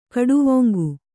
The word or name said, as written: ಖಟ್ವಾಂಗ